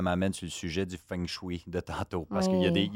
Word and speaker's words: feng shui: La prononciation, je crois, c'est feng shui. Mm-hmm. Ça s'appelle F-E-N-G-C-S-H-U-I. Je répète, mêlé, là. – F-E-N-G. – Oui. – S-H-U-I.